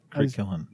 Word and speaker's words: Crit Killen: Right, Crit Killen.